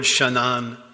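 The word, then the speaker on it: Shanan (שנן): That word diligently is translated from the Hebrew word shanan. It's spelled S-H-A-N-A-N, Shanan.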